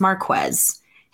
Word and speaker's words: Marquez: I'm still hearing it Super Bowl week, and I'm like, yo, reporters, get the pronunciation right. It's Marquez. His teammates literally call him Quez as a nickname.